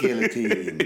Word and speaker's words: guillotine: I'm going to play some sounds of the guillotine. – Yeah. – Guillotine rhymes with gelatine, but you don't want it wobbly.